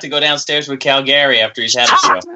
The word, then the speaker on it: Calgary: It's definitely Calgary, but I really enjoy you saying Calgary.